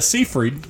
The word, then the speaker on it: Seyfried: So it's Amanda Seyfried, isn't that how you pronounce her name? – Yeah, yeah.